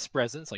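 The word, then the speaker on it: Spresense: I think the pronunciation is Spresence, like presence.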